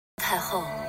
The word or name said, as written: taihou